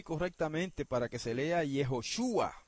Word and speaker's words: Yehoshua: pero cuando se transcribe se transcribe generalmente como Yehoshua y se lee Yehoshua, Yehoshua.